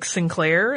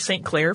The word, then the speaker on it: St. Clair: In 1788, Arthur St. Clair, who sometimes you'll hear it pronounced more like Sinclair, and there's some debate about the correctness of which one, but we're going with St. Clair for this episode.